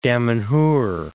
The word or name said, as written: Damanhur